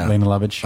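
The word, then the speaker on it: Lene Lovich: She Lena Lovitch. Was that how you say her name? – Lena Lovitch.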